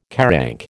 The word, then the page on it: QARANC: American English